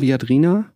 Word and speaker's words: Viadrina: Ähm, du hast Kulturwissenschaften an der Europa-Universität Viadrina. Ist das richtig ausgesprochen? Mhm. Viadrina? Ja.